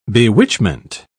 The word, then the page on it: bewitchment: British English